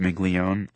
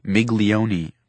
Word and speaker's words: Miglione: How do you do, Mrs. Miglione? I'm happy to meet you. – Joel pronounced our name right. Not everybody does. A lot of people say Miglione, but the E on the end is silent.